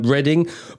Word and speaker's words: Reading: Reading, beautiful place. It definitely doesn't look like it's spelt Reading.